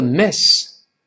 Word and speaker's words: miss: 再说后面那个 miss，老师说过，中间的短音千万不要读成 e，要发成 miss。